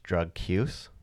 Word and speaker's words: Drukqs: My first song, let's just say it's called Drux, but it's spelled D-R-U-K-Q-S. – Oh, okay. – But it is supposed to be pronounced Drug Cuse. So Drug Use.